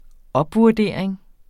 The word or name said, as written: opvurdering